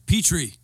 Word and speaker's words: Petrie: Petrie. – I thought it was, but I didn't want to say it wrong. – Yeah. I understand. – Like that. – The dish, right? – Like the dish.